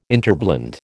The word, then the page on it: interblend: American English